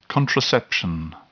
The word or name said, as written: contraception